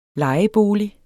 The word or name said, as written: LEJEBOLIGEN